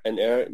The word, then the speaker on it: Ennerre: And Ennerre is how you would pronounce the old brand from the late 80s, early 90s. Like Ennerre, it's like Ennerre, so Ennerre. That's how they would say it.